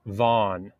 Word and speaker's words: Vaughn: En español, mi nombre está pronunciado Balgan, pero en inglés es Vaughn.